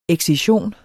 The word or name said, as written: excision